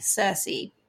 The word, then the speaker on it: Circe: So it's C-I-R-C-E, Cersei.